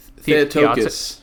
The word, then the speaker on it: Theotokos: First thing, Theotokos, am I saying that right? Theotokos? – Theotokos. – Theotokos. Okay, cool.